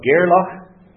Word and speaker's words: Gairloch: Well, Rue, as Julian will know, I had to look it up, is on the east side of Gairloch. Did I pronounce that properly? – Yes.